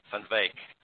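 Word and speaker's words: van Wyk: Because now you've written a charming story of relating to Marietta van Weeks. Is that how you say her name? – Van Weeck. – Van Weeck. – Marietta van Weeck would be how it's pronounced. Yeah.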